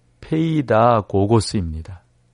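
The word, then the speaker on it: 페이다고고스입니다 (παιδαγωγός): 페이다이고스입니다. 좀 더 정확히 발음을 해볼까요? 페이다이고고스라고 말을 할 수가 있죠. 그러니까 율법은 우리의 몽학선생 즉 페이다고고스입니다.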